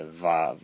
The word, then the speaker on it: vav: In Hebrew, the sixth letter is vav, like we pronounce a vav, V-O-W.